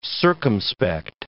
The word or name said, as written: circumspect